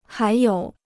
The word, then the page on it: 还有: hái yǒu